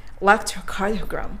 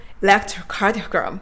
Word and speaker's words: electrocardiogram: エレクトロカーディオグラムじゃなくて、 electrocardiogram electrocardiogram ということで今回はですね、エレクトロカーディオグラムを electrocardiogram にみんなで変えていきたいと思います。